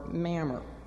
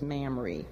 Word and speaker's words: Mamre: or Mamre, which my husband tells me is Mamre. So I'm sorry, I've been pronouncing it wrong all these weeks.